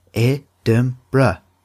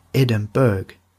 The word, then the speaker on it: Edinburgh: So, I want to give you all a quick tip that Edinburgh is pronounced Edinburgh, Edinburgh, like that, not Edinburgh. This is a mistake that catches many people out, so don't feel bad if you got it wrong.